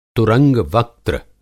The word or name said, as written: तुरंग-वक्त्र